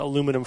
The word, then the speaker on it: aluminum: You know, it's two more syllables. – No, I think it's more than that. – Three. – It's three more. – Aluminum. – Close enough. Whatever. – Yeah, four. – Three or four if you say it wrong, like the English did.